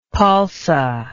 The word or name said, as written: palsa